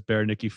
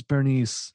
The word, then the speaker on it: Berenice: I thought it was Bernice. – Okay. Right, I know, because that's how it's spelled. It's spelled like Bernice. Who wouldn't pronounce it Bernice? – Yeah. – Unless you knew the myth.